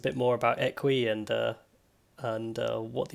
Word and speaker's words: Eqwe: Well, so the app is called Equi, and that's spelled EQWE. That name kind of, the name comes from Equi as in equidistant, and we, W-E, as in a sense of kind of togetherness.